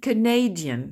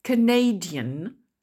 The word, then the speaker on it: Canadian: Attention là à la prononciation Canada, adjectif Canadian, je vais encore répéter, Canada, le Canada, Canadian, la personne Canadian, les personnes, the Canadians, et la langue Canadian.